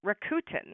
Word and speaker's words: Rakuten: Another one like Dosh, number five on my list today, is Rakuten. It is spelled R-A-K-U-T-E-N.